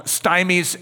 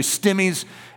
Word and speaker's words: stimmies: I know that you were wanting to call them stymies and strangies, but no, it's from stimulation and from strangulation. So it's actually stimmies and strangies, okay? So get your pronunciation correct.